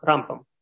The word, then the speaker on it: Рамбам: эти имена различают по ударению, да, то есть Рамбам, это тот, который, мудрец, который, значит, жил в Египте, то есть и он жил около, ну, там, действительно, около тысячи лет назад, да, то есть это его ударение на первый слог, Рамбам, да, а Рамбан, значит, мудрец, который жил в Испании, ну, немного позже, да, то есть это Нахманит, да.